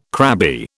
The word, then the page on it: crabby: British English